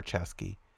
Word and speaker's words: Ceschi: If you're looking for Ceschi, Ceschi is spelled C E S C H I, and this track is called 2020 BC.